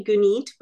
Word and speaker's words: Gunit: It's actually Gunit, but it's not an easy one to know.